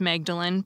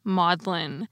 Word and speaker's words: Magdalen: And second, I told you that the author of the piece about tea was from Magdalen College, which is not pronounced Magdalen, but is pronounced Maudlin. It's Maudlin College.